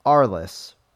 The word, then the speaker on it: Arles: I wasn't going to say anything about it, but I couldn't resist when I heard Tim identify the town where I saw the drawing of Ailes. It's Arles, pronounced Arl, an ancient Roman outpost in Provence, where Van Gogh, pronounced, made some of his most famous paintings.